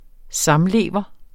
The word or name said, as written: samlever